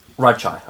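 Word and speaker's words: Radchaai: So you, you pronounce it Radachi? Radchai, I pronounce it. Radchai? Yeah. Okay.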